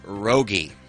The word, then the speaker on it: Rogge: And I think maybe the most important thing that we all learned as a group today together is it is pronounced Rogge. – Yeah. – Rogge. For years, I have seen your name written and not spoken. So in my mind, I've always thought it was like Rogge or Rogue or something.